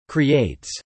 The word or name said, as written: creates